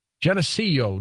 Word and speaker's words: Geneseo: Geneseo, New York. Did I pronounce that right, Jim? – Yes, it is.